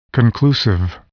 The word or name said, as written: conclusive